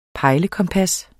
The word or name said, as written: pejlekompas